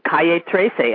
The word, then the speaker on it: Calle Trece: That is, we are coming out of Southern California, and that is Calle Trece. Am I saying it correctly, Alfredo? – Yes, that's correct.